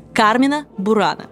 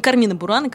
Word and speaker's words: Carmina Burana: Так вот, правильно произносить следует Кармина Бурана, а не Кармина Бурана, как это делают сегодня очень многие.